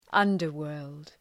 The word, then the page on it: underworld: ‘ʌndər,wɜ:rld